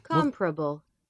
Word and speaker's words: comparable: There is no alternate pronunciation whatsoever. – Comparable. – Anyway, the guys were reading a commercial and D kept saying comparable. I mean, it's comparable with... No, it's not comparable with anything. It's comparable.